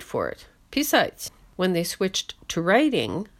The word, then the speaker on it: писать: Let's take the verb писать, stress on the last syllable, which means to write, to draw, or to compose.